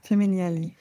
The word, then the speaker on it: Femminili: Und da gibt es in deinem Buch so eine Gruppierung von Menschen, von denen ich noch nie vorher gehört hatte, die Femminili. Spricht man die so aus? – Ja, ich bin keine Italienerin, aber Femminili.